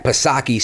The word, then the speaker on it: Psaki: So kudos to Steve Doocy for asking Jen Psaki, silent P, of course, that poignant question.